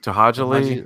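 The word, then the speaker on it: To'hajiilee: Is it Tohaji? – Tohajali. Yeah.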